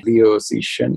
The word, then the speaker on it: Liu Cixin: Liu Cixin. And now everyone who speaks Chinese is going to laugh at my pronunciation. – No, you pronounced it correctly.